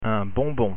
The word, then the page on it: bonbon: French